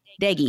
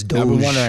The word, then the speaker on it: Deggy: Deggy. – Okay. I thought it was Doge or something like that. – No. – Okay. – No, everybody, it's Deggy.